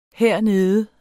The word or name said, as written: hernede